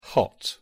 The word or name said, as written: hot